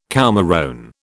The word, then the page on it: coumarone: British English